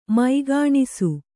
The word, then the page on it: ಮೈಗಾಣಿಸು: Kannada